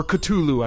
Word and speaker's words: Cthulhu: I think it's the ones that made Cthulhu Saves the World, or Cthulhu, I guess, if you're a purist, is how you pronounce that. I think I'm just going to stick with Cthulhu.